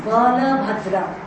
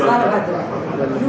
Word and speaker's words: Balabhadra: So, it is Balabhadra. Not even Bal. Bal means child. It's Balabhadra.